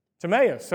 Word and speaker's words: Timaeus: Jim, how'd you pronounce it earlier? You corrected me after the first service. Timaeus, okay. So according to one of our elders, it's Timaeus. So Bartimaeus, son of Timaeus, a blind beggar, was sitting by the roadside.